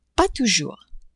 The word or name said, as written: pas toujours